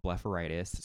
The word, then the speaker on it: blepharitis: But so it's an eye disease called blepharitis.